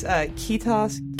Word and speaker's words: kiitos: And that's the only word that I knew is kiitos.